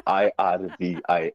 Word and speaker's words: Irvin: We're laughing about this, because off air there was a big debate about Ivan's name and how it's pronounced. Some were saying it's Irvin, I was saying it's Ivan, but his parents say it's Ivan, and I'm gonna stick with the people that named you.